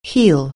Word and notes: heal: anglais

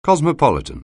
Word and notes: cosmopolitan: anglais

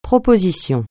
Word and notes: proposition: francais

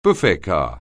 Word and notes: buffet car: anglais

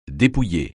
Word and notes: dépouiller: francais

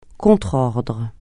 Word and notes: contrordre: francais

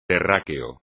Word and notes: terráqueo: espagnol

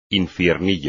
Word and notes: infiernillo: espagnol

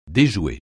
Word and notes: déjouer: français

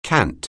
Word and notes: cant: anglais

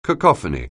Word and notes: cacophony: anglais

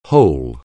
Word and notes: whole: anglais

